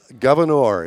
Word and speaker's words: Gouverneur: Gouverneur. It's G-O-U... – Uh-huh. – V-E-R. – It's already a French word. – N-E-U-R, Gouverneur. – That's funny that I came up with that. – It rhymes with manure.